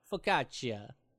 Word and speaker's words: focaccia: Nice focaccia bread. I assume that's how you pronounce that. Focaccia. F-O-C-A-C-C-I-A.